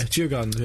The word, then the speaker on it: Djurgården: Djurgården, I think that's pronounced. – Yeah, Djurgården, yeah.